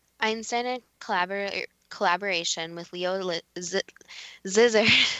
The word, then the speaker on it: Szilard: Szilard.